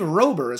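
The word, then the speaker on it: Robert: I believe Rober is how we say it.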